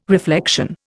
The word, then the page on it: reflection: Indian